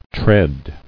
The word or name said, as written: Treading